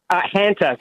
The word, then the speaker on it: Hanta: it's the Hunter virus, am I saying that right? – Hanta. So discovered in the Hantan River in South Korea, so Hanta virus.